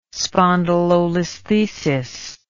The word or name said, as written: spondylolisthesis